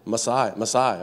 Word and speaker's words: Maasai: Maasai.